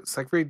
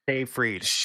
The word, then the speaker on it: Seyfried: And Amanda Seyfried does honestly. – Seyfried. – Seyfried. Sorry, I can never pronounce her surname properly.